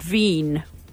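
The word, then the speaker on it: Wien: I believe locally it's pronounced Veen. And spelled with a W.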